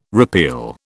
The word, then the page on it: repeal: British English